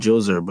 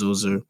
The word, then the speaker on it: Djoser: I call him Djoser, but apparently his name is pronounced Zoser.